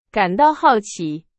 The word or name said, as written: 好奇